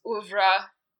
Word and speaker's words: oeuvre: So now that I've given this whole list, I guess my question to you is sort of what of his oeuvre, you have a history with the French language, and I don't know if I'm saying that right.